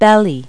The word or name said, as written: belly